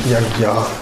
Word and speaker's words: yajna: Yajna is usually pronounced gya, yagya, yajna, yagya.